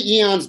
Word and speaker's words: aeon: How many eons do reptiles have? Okay. Miguel says aeon. How do you say it? Aeon? – Aeons. There's no right or wrong. We don't know. – I say eon, but maybe that's, I don't know.